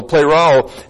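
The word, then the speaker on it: pleroo (πληρόω): Looks like this. P-L, long E-R-O, long O, pleroo.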